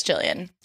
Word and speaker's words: Gillian: Is it Jillian or Gillian? Because now I'm questioning. – I think it's Jillian because the whole movie, she's like, Jilly.